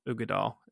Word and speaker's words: Uigeadail: It's Ugadal Scotch, UIGEADAIL. Ugadal is how you pronounce it.